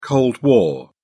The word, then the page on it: cold war: British English